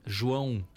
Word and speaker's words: João: How do you say that first word? – João. – João. Oh, João. – Oh, yeah. – It's a Portuguese word for John.